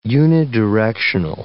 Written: unidirectional

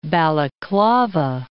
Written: Balaklava

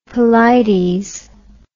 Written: Pelides

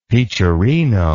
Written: peacherino

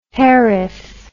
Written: hairif

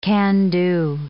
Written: can-do

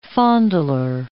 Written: fondler

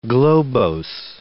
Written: globose

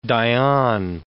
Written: Dayan

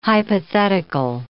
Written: hypothetical

